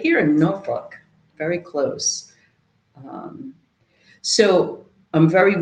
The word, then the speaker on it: Norfolk: And I'm in this study room, and it is Norfolk. That is how you say Norfolk. I'm from Jersey. That's how I say it. Tiffany correcting me.